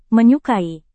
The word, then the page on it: menyukai: Indonesian